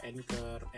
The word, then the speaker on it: Anchor: Karena equipment gue, gue nggak tahu, gue nggak belum waktu itu belum kenal kayak aplikasi buat podcast gitu kayak Spotify, kayak Anchor, Anchor apa Anchor sih bacanya?